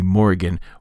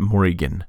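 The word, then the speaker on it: Morrígan: The stock English version, like the name of the character from Darkstalkers and what have you, seems to be Morrigan with a short I, but I think that the accent over the I makes it longer, that it's Morrigan, like an E sound.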